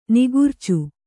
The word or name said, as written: ನಿಗುರ್ಚು